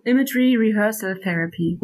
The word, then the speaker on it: Imagery Rehearsal Therapy: Ähm, Imagery Rehearsal Therapy. Spreche ich das halbwegs richtig aus? Wie würden Sie es aussprechen? – Ja, Imagery Rehearsal Therapy. – Rehearsal, okay.